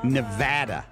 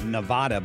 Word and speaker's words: Nevada: I don't know, out in Nevada. And I used to always call it Nevada, but I guess it's pronounced Nevada. And people that live there will get mad at you if you mispronounce it.